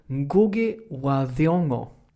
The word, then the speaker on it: Ngugi wa Thiong'o: Ja, och jag är en av de få svenskar som faktiskt kan uttala hans namn korrekt. Eftersom jag har bott i Kenya och umgåtts med folk i den etniska folkgrupp som han tillhör. Mm. Nämligen Kikuyerna. Jag lärde åtminstone mig hur saker skulle uttalas. Så han heter Ngugi wa Thiong'o.